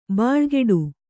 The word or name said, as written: ಬಾಳುಗೇಡಿ